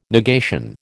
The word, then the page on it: negation: American English